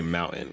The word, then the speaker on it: mountain: The big thing is, how do you say mountain? Because if you say the T, then you're a weirdo.